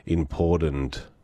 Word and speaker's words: important: Rachel says also, the other one is important, being pronounced with a D, not a T. Ah, important.